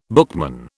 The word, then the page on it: bookman: British English